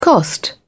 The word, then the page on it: cost: British English